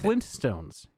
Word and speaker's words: Flintstones: Flintstones. You have to pop the T.